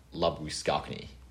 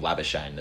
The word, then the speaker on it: Labuschagne: They pronounce his album surname like Labuschagne. So I think that's how it's meant to be pronounced, but the Australian public's just called him Labuschagne that stuck.